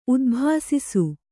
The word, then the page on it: ಉದ್ಭಾಸಿಸು: udbhāsisu